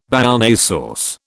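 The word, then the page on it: Béarnaise sauce: British English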